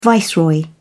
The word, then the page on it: viceroy: British English